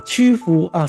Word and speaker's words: 曲阜: 曲阜啊，谢谢老师，谢谢喜乐啊。还有嵩山这带啦哈，当然之前比较早开发的城市就是西安啊、洛阳啊、郑州啊、安阳、开封跟曲阜啊这几个啊，